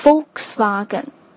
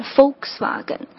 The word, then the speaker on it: Volkswagen: 大众，这个也是在中国非常受认可的一个牌子啊，下面有很多很多系列的车，那它的正确发音呢，实际上是 Volkswagen。其实我也一直念 Volkswagen，因为英国人也是这么念的 Volkswagen，但是它的正确发音实际上应该是 Volkswagen。